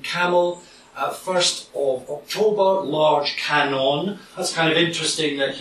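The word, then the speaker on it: Kannon: the term Canon, the Japanese pronunciation of Guanyin, is how this particular Buddhist figure is known.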